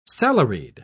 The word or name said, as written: salaried